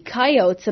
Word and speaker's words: coyote: So I guess the people kind of transformed it into the three syllable coyote. So I think coyote is probably the original pronunciation.